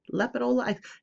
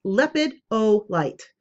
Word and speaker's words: lepidolite: Also, the stone called, and I'm going to mess it up, lepolite, lepid, lepidolite, lepidolite. That's how it's spelled. L E P I D O L I T E.